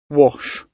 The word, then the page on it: wash: British English